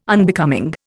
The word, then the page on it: Unbecoming: Indian